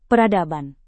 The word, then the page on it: peradaban: indonesian